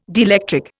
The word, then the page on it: dielectric: Indian